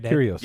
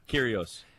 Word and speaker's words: Kyrgios: How do you say his Kyrgios? – Name? – Kyrgios. – Yeah, Kyrgios.